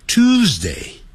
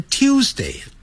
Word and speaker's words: Tuesday: 现在讲啦，今天是礼拜二呢， It's 现在这个没有问题啦， Tuesday Tuesday 有人念成 Tuesday，啊哦，在美式音标它不是这么念的，念 Tuesday， It's Tuesday today， Tuesday。